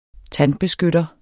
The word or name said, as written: tandbeskytter